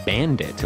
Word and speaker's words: BVNDIT: All right, our next and final artist for today is a new Korean girl group called Bandit, or at least I'm pretty sure that that's what it's supposed to be pronounced like. It is spelled B-V-N-D-I-T, and it's an acronym for Be Ambitious and Do It. That's the letter N, not the word and.